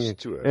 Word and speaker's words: Stewart: A ver si me bueno, Stewart, hay que pronunciar bien, que luego Stewart, me lo dicen. Stewart. Es James Stewart para los americanos.